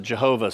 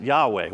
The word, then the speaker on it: Yahweh: When God gives His name at the burning bush to Moses, it's what we know of as some people pronounce it Jehovah, some people pronounce it Yahweh, YHWH.